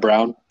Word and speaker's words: Brown: Like, Adam Brown. Starts writing it down. How do you spell that? And I'm like, B-R-O-W-N.